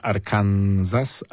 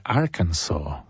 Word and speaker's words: Arkansas: I ta jego wspólnota, mnisza, tego Johna Michaela Talbota, to jest w stanie, który my nazywamy Arkansas, a to się wymawia Arkansas.